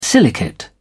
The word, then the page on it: silicate: British English